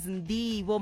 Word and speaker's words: Ndivho: My name is Ndivho Makhwanya. It is spelled out as N-D-I-V-H-O.